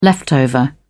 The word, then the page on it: leftover: British English